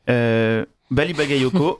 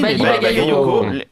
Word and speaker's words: Bali Bagayoko: Bali Bagayoko, LFI. – Bravo. Tu l'as bien prononcé. – Bah oui, Bali Bagayoko, maire de Saint-Denis, et qui, en plus de ça, enfin.